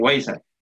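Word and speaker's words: Weezer: con Weiser o que Wazer? ¿cómo es? Con Weiser. Mae.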